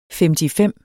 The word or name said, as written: femtifem